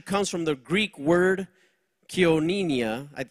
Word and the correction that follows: Koinonia: Koinonia, excuse my pronunciation for all you Greek scholars, which means holding something in common.